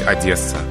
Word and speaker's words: Одесса: Но вы знаете, нормы русского литературного языка велят нам произносить Одесса. Произношение Одесса по-русски неправильно. Там надо согласные «д» Согласный «д» в этом слове нам надо смягчать.